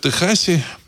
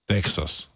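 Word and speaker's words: Texas: А то, что происходит в Техасе, на мой взгляд, э, вот ну, сейчас обсуждается, что значит, э, э, Национальная гвардия Техаса, вообще правильно говорить не Техас, а Техас. Американцы говорят Техас. Тексас. Вот. И оно пишется через X, это не Техас, а Тексас. Вот.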